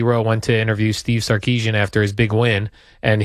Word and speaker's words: Sarkisian: Arch Manning got in trouble for somebody showing him an AI video of Steve Sarkisian.